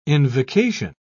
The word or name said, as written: invocation